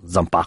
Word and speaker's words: Zampa: Diventa pirata, ma torna in patria con il nome di appunto Zampa, o Zampa come si dovrebbe pronunciare alla francese.